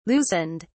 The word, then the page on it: loosened: British English